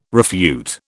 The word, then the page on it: refute: British English